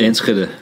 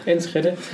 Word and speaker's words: Enschede: So, jetzt hätte ich gerne von dir, ich wollte eigentlich Essenstipp von dir haben, wo man in, wie heißt das, Enschede, wie spricht man das aus? wo du in Holland, Enschede. Enschede.